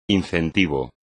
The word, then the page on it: incentivo: espagnol